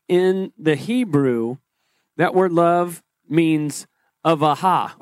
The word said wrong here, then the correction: ahava: or actually that's how you pronounce it, ahava.